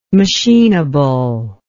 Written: machinable

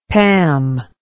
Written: pam

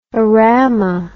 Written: orama

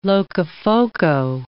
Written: Locofoco

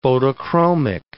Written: photochromic

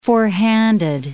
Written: forehanded

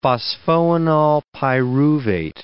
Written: phosphoenolpyruvate

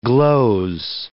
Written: gloze

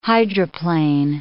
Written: hydroplane

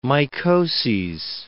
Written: mycoses

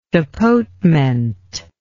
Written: tapotement

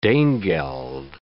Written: danegeld

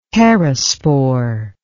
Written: perispore

